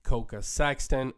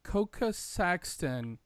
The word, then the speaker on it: Koka Sexton: With that being said, I'm going to go ahead and turn things over to the guest interview with Coca Sexton from Hootsuite. All right, everyone. Today, I am joined by none other than Coca Sexton.